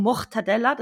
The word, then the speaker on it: Mortadella: Mortadella, so wird es übrigens richtig ausgesprochen, Mortadella, das ist mit einem R und okay.